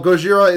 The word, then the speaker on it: Gojira: Oh, Gojira is the Japanese spelling slash pronunciation of Godzilla.